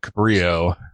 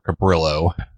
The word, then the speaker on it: Cabrillo: And the logs that it supports, I know it supports ADIF and Cabrillo, or Cabrillo. I want to say it like it's a... like the actual Spanish word, not our bastardization of it.